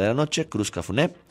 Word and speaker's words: Cruz Cafuné: Además, estamos regalando también dos pases dobles para que usted se vaya a ver a Cruz Cafuné o Cruz Cafún, ¿cómo se pronuncia? Cruz Cafuné,